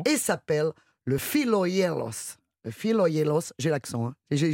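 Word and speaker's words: Philogelos: Je suis Philoyelos. – Non, Philoyelos. Philoyelos. – Philoyelos. – Ça s'écrit Gelos, mais ça se dit Yelos.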